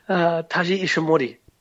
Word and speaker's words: Taiji Ishimori: Taiji Ishimori? I can't pronounce his name. – Taiji Ishimori.